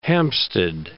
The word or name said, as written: Hampstead